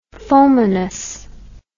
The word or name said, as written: fulminous